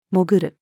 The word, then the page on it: 潜る: female